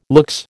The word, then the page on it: luxe: American English